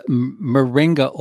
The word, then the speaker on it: moringa: That's the moringa oil, right? Did I say that right? – Yeah, yeah, you said that right.